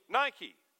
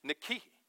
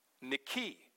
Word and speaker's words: Nike: Both of those words derive from the same Greek word we would say today, like the shoe company, Nike. But that's not correct. If you want to pronounce it properly, it would be Nike. Nike. Last syllable gets the accent and it's long. Nike, not Nike.